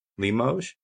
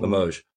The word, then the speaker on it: Limoges: Limoges. Mm-hmm. Limoges.